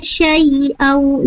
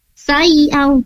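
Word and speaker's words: syai'a (شيئا): La yakhluquna syai'au. Nah, tadi di sini Ibu tadi membacanya dengan sayau, seolah-olah itu huruf sin, padahal di sini menggunakan huruf shin ya, sampai MG.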